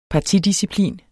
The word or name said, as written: partidisciplin